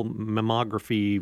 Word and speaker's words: mammography: If you send out that mobile mammography van, did I say that right? – Yep, you did.